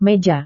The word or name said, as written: meja